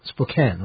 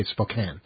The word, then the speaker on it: Spokane: Spokane, right? – Yeah. – It's spelled Spokane, but you're not supposed to say it that way. You're supposed to say Spokane.